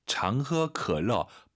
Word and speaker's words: 喝: 常喝可乐. 발음 좀 도와드릴게요. 이 어 발음하실 때 마시다 허 아니고 흐 아니고 굳이 말씀을 드리면 흐 두 글자죠.